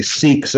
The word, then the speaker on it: Sikh: By the way, S-I-K-H, we always say Sikhs, that sounds nice. In English, Sikh sounds bad, but Sikh is actually how they pronounce it. Sikh. No, they don't say Sikh like it's a Hebrew word. Siach. It's really Sikh.